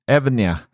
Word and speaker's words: Evnia: det är lite svårt att uttala det, Evnia kallar de det för, som då är fyra stycken gaming-skärmar som de har släppt.